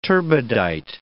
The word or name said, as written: turbidite